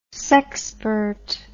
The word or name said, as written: sexpert